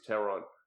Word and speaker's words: Theron: Well, she's South African, so chances are the TH wouldn't be pronounced. It'd be just a T. So it'd probably be like Charlize Theron.